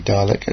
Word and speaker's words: Dalek: It's clearly Dalek. As you listen to a Dalek, he says, Dalek will say, Dalek. Well, the Dalek announcer has made it quite clear how to pronounce the word Dalek.